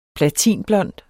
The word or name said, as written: platinblond